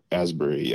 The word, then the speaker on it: Asbury: Asbury. Oh, Ashbury? Asbury? Is that how they pronounce it? Asbury? Asbury, Asbury yep.